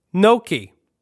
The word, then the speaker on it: gnocchi: Gnocchi. It's pronounced N-O-K-E-E.